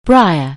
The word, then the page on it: briar: anglais